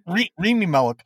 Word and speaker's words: Rami Malek: Rami Malek. A crack. – Rami Malek.